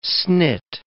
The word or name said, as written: snit